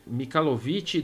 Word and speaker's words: Mikhailovich: Vou tentar, me corrija aí se eu fazer alguma atrocidade de pronúncia, mas o nome dele aqui é Fyodor Mikhailovich Dostoevsky. É isso? Mikhailovich? É isso aí. Isso, Mikhailovich. Isso, Nossa, Mikhailovich.